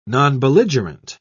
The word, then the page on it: nonbelligerent: British English